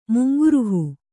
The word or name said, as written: ಮುಂಗುರುಹು